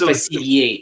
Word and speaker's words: Facilier: It's Facilier. It's French. – Yeah, that's probably what it's I... basically say facile. That's easy in French. Facilier.